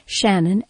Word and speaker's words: Shannon: Shannon, S-H-A-N-N-O-N, Walbran, W-A-L-B-R-A-N.